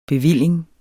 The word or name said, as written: bevilling